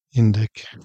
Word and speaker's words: indyk: Can you say indyk? – Indyk. – There's a K at the end. – Indyk. Indyk. – No. Oh, that's not how you say it. It's indyk.